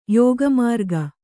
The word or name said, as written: ಯೋಗಮಾರ್ಗ